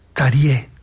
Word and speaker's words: Terje: Casualmente lo he mirado en internet, que le hacen una entrevista y también le preguntan cómo se pronuncia. Terje es Tarie, Todd Terje.